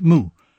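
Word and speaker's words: mu (木): In Mandarin it is pronounced mu, but you hardly need to know the sign to guess it is a tree.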